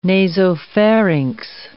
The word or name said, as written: nasopharynx